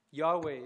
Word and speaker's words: Yahweh: Yahweh is how they try to pronounce it by inserting vowels.